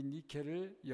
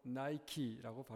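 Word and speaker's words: Nike: 이 니케를 영어로 나이키라고 발음합니다. 이 승리의 여신이에요.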